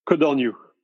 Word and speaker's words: Codorniou: What's the correct pronunciation? – Cordonnier. – Cordonnier. There we go.